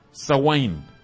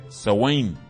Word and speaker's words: Samhain: Ahora, los celtas adoraban al dios Sawain, esa es la pronunciación, se escribe Samhain, pero se dice Sawain.